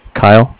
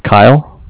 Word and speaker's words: Kyle: Kyle. – I'm sorry? – Kyle. K-Y-L-E. – Kyle? Spell it again. – K-Y-L-E. – K-Y-L-E.